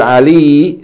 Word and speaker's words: عَلِيٌّ: So remember that Ali, the word Ali, is written with, is pronounced with a shadda on the ya. It's two ya's. Okay, the first ya is elongating the kasra from the lam, Ali. And the second ya is a consonant that has a vowel.